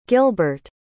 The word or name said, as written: gilburt